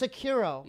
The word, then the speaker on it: Sekiro: Oh, it's Sekiro. – Yeah. – S-E-K-I-R-O.